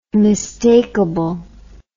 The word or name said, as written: mistakeable